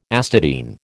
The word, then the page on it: astatine: American English